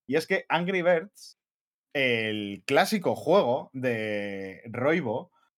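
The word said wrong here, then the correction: Rovio: eh, Robio, perdón, que es que siempre, siempre lo pronuncio mal, eh, eh, de Robio.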